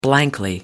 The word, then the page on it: blankly: British English